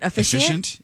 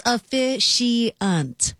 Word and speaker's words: officiant: Am I saying it wrong? – You said officiant. – Efficient? It's efficient. It's an efficient? – It's close. You're close. It's close. It's officiant.